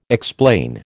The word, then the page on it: explain: American English